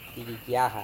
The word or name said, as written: pihikiaha